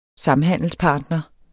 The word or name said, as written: SAMHANDELSPARTNER